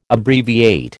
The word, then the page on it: Abbreviate: American English